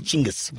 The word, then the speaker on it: Chinggis: Okay, the real pronunciation in any sort of Mongolian would be Chinggis. – Genghis. – No, it would be Ch. – I called him Chinggis. – No, it's pronounced Ch. So it's Chinggis.